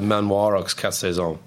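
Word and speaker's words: Manoir Quat'Saisons: Yeah, the Manoir, Quat’Saisons.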